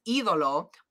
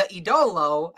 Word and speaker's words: Idolo: I think what happened was, because Nigel said it right, so I think they had it in their notes phonetically spelled as the E Do Lo, right? So Nigel said it right, because he emphasized the right do, the E, because so it's Idolo, but poor Ian was emphasizing the wrong thing, and he said the Idolo, and I said, damn it.